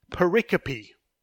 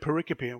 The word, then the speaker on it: pericope: I remember when I started my MABC, they used this word pericope. What on earth is that? It's like the word periscope without the letter S, but it's obviously pronounced very differently.